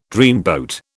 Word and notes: dreamboat: British English

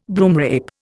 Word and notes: broomrape: Indian

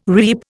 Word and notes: reap: Indian

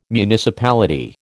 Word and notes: municipality: American English

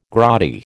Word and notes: grotty: American English

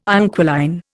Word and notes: inquiline: Indian

